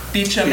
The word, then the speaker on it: Beauchamp: Beecham and Childress, his name is spelled like Beauchamp, so I always have to think of it, Beecham and Childress, have really come as the experts and have written whole books about these four in particular.